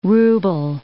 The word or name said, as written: rouble